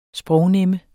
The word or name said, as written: sprognemme